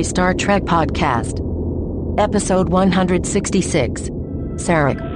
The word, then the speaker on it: Sarek: No, Ken, it's Sarek.